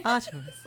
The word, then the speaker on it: Artemis: Artemis.